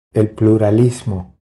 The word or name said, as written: El pluralismo